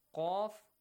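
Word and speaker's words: Qaf (قاف): Qaf, good job. Qaf, whenever qaf, the letter qaf has a fatha or a dhamma, it's pronounced heavily.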